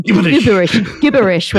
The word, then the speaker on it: gibberish: which is pronounced gibberish with a hard G, which is just gibberish.